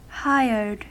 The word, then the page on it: hired: American English